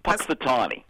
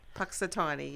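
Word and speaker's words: Punxsutawney: Puxatawny. – Puxatawny, yeah.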